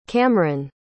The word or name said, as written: Kamren